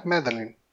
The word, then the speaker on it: Madeline: Madeline? Ma é, Madeline.